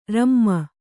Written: ರುಮೆ

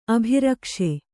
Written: ಅಭ್ರಕೇಶ

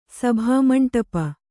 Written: ಸಭಾಮಂಟಪ

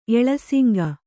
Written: ಎಳಸಿಂಗ

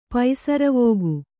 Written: ಪಸುರ್ವಕ್ಕಿ

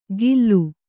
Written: ಗಿಲ್ಲು

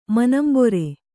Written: ಮನಂಬೊರೆ

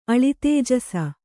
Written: ಅಳಿತೇಜಸ

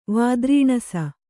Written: ವಾದ್ರೀಣಸ

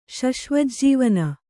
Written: ಶಶ್ವಜ್ಜೀವನ

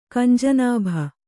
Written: ಕಂಜನಾಭ

